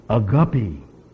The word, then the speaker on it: agape: And he said, agape. And I said, really? I said, I always thought agape was a little fish that people raised in a tropical fish tank. And he says, no, he says, that's the correct pronunciation.